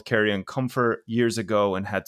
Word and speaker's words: Carrion: One of our listeners, Eric, tweeted us after, and he said that it's Carrion, rhymes with Marion, and it actually means dead meat, as in literally rotten flesh.